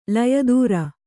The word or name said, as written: ಲಯೋತ್ತರ